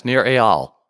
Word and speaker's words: Eyal: Yeah, he was on the podcast, Nir Eyal. – Oh, is that how do you say it? Nir Eyal. – Okay. Yeah, like it's A-Y-A-L.